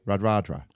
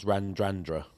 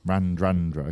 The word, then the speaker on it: Radradra: Radradra. – No. – Okay. – Two silent ends. Randrandra. – Randrandra. Okay. Fine.